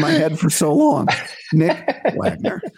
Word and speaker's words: Wagoner: And I asked him the other day, I said, "What is the O silent or not?" He said, "Yes, the O is silent, Nick Wagoner," right?